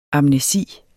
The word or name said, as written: amnesi